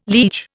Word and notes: liege: Indian

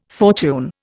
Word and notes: fortune: Indian

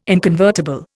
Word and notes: inconvertible: Indian

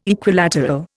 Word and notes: Equilateral: Indian

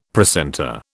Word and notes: precentor: British English

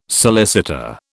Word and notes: solicitor: British English

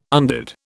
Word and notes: undid: British English

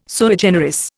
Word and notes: sui generis: Indian

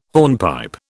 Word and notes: hornpipe: British English

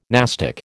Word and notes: nastic: American English

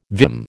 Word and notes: vim: American English

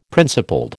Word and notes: principled: American English

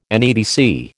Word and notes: NEDC: American English

